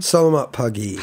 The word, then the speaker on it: Selamat Pagi: I'm going to say it wrong again. – Selamat Pagi. – Pagi, yeah. Pagi.